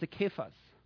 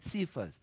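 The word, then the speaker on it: Kephas (Κηφᾶς): Okay, I pronounce it as Kephas. And some of you think, oh, I know you speak broken English, so it's a Cephas. No, that's the English version of Kephas.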